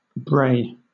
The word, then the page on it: brae: British English